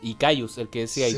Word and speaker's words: Kyuss: Y después en el tiempo a mí me hablaban harto de la banda Cayus, que es con K-Y-U-S-S.